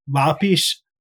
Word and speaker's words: Lopes: Lapis Warfield. So it's Portuguese, but in Spanish, lapicera or lapis is a pen or pencil, but it's a different pronunciation of Lopez.